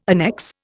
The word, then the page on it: annexe: Indian